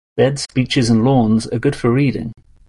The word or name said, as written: beaches